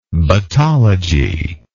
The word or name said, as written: battology